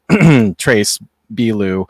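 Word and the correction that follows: Beaulieu: uh, Bolu, Bolu is how you pronounce yeah. that name.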